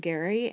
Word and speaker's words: Gerry: My husband Gary and I, it's spelled Jerry, G-E-R-R-Y, but it's pronounced Gary.